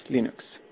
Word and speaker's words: Linux: Hello, this is Linus Torvalds and I pronounce Linux as Linux.